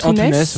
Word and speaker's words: Antunes: Euh, alors moi, je fais partie des rares personnes qui disent Lucie Antunes, mais je crois qu'on dit Antunes.